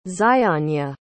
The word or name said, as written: zyanya